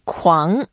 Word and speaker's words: 狂: Okay, let's learn how to say it first. Kuang. – Kuang, which tone? – Second tone. – Second tone. Kuang.